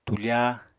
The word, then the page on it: tufa: Apatani